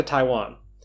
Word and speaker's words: Taiwan: Some of them got sent back to Taiwan. I said that very strangely. Got the emphasis on the wrong syllable. Some of them were sent back to Taiwan.